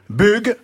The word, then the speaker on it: Bug: Bref, Bug va connaître un succès considérable. – Bug. – Écoutez Nagui, en français, B, U, ça se prononce bu, pas B.